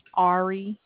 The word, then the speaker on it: Arie: Ari. – Could you spell that? – A-R-I-E.